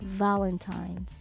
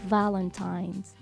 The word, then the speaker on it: Valentine's: Valentine's」の発音だけ注意してください V V の音なので上の歯で下唇をちょっと噛みながら「わわって震わせるような感じで Valentine's Valentine's と発音します